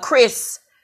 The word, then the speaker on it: Crys: Her name is Chris, and it's spelled C-R-Y-S Speaks.